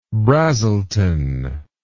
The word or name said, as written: Brazelton